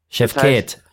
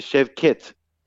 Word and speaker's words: Chefket: Bei türkischen Namen wird immer die zweite Silbe betont. Chefket. Das heißt, es heißt Chefket.